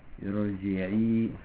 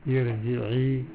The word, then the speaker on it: ارجعي: Le Aïn, on fait attention. Irji'i, c'est bien Aïn. – Irji'i.